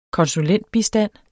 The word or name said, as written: konsulentbistand